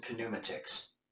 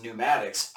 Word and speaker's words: pneumatics: And I went up and I read the sign on the door and I said, hydraulics and pneumatics. And when they revealed it, you know, no, it's pronounced pneumatics, I was mad.